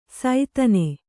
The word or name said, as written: ಸೈತನೆ